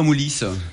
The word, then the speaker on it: Moulis: Et on va à Moulis ou à Mouly, en Médoc. – Dans le sud-ouest, on prononce toujours la dernière comme ça. – Ouais, on a tendance à dire, ou entendre Moulis quand même.